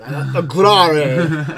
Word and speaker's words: Aguirre: Aguirre. – Yeah, you got to put some shit behind it. – Aguirre.